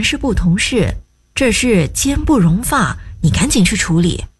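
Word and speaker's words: 间不容发: 一样啊，正确读音是奸不容发。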